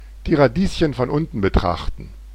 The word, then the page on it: die Radieschen von unten betrachten: /diː ʀaˈdiːsçn̩ fɔn ˈʊntn̩ bəˈtʀaχtn̩/